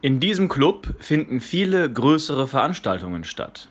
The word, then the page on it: Veranstaltung: German